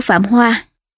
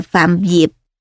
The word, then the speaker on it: Phạm Diệp: Lưu Diệp ở phần trước đã phiên âm là Lưu Hoa, thật ra chữ này đọc là chữ Diệp hoặc Dẹp. Phần mở đầu truyện có ghi, sách Hậu Hán Thư của Phạm Hoa, xin độc giả sửa lại là Phạm Diệp cho đúng.